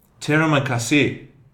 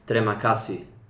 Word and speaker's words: terima kasih: Oh, I think I might have to correct you here and say that it's pronounced terima kasih, as I took an Indonesian class in high school.